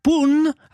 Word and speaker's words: La Punt: Et moi, j'ai appris comme parole, je ne savais pas, qu'on dit, on dit pas le pont, on dit la, donc c'est féminin, Pun, alors qu'il y a un T à la fin, mais on ne le prononce pas, la Pun.